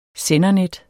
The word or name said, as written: sendernet